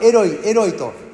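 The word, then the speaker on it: エロイ エロイ: イエス様はおそらくエロイエロイと発音してるんですけれども、別に間違いじゃないです。それをエロイエロイをヘブル語に訳せばエリエリになりますから、全然間違いではないんですけれども、でも当時イエス様が発音されたのはエロイエロイだと思うんです。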